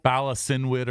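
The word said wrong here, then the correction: Bala Cynwyd: or something like that? – I think it's Balakinwood is how it's pronounced.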